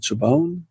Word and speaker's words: Chabon: I think he pronounced it Chabon. My relatives that have that last name pronounce it Chabon. – It must be Chabon.